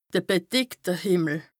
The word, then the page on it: de bedeckte Hìmmel: Bas-Rhin; Strasbourg